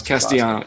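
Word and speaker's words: Castellanos: I don't know how to pronounce Castellano. Yeah, you got it right.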